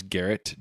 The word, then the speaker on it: Garrett: And I'm at Two Views Garrett, G-A-R-R-E-T-T, because nobody ever spells my name right, so I have to spell it for you.